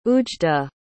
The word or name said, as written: oujda